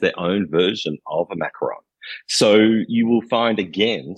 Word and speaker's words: Macaron: Your town is also famous for the macarons.